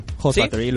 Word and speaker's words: Hoth Factory: Y hoy tenemos unos invitados especiales que vienen a través de Hof Factory. ¿Lo he dicho bien, chicos? – Sí, Hof Factory, los hemos oído...